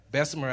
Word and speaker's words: Bessemer: I want to follow up a little bit on what Aaron asked you, and please forgive me if I don't get the name right, but it's Bessemer, Alabama? – Bessemer, Alabama.